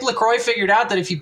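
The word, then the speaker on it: La Croix: You see, Donovan, you mispronounced it before because it is a LaCroix.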